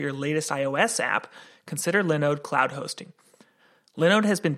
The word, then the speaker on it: Linode: Linode, Linode, Linux, Linux, you know how that goes. I think that the company itself pronounces it Linode, and their pitch is basically virtual hosting.